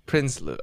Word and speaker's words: Prinsloo: But Kyle Prinsloo, I should have asked you before. – That's great. I'll take it. – How do you say it? – Kyle Prinsloo. – Prinsloo.